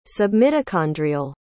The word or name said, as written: submitochondrial